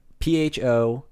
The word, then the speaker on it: pho: And if you don't know how PHO is pronounced in uh, whatever, I forget if it's Korean or it's Vietnamese. I promise it's a uh, Vietnamese. It's pho.